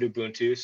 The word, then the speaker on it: Ubuntu: Yeah, and I pronounce it Ubuntu, so the people you talk to, you might hear something different.